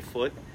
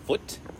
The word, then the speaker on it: Foote: Foote? E? – Yes. – Foote. I think you pronounce it Foot. Foot to be fancy. Like, my name is Shelby Foot, not Foot.